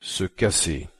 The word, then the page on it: se casser: French